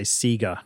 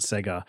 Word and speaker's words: Sega: Michael obviously had like a real affinity with, and apologies for the pronunciation, but in Australia we say Sega, not Sega.